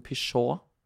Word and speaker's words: Peugeot: Jeg husker at vi har hatt en Peugeot Um, fordi det var sånn jeg lærte meg at ting ikke alltid uttales sånn som det staves. – Ja. Den der har de fleste gått på. Peugot, Peugeot.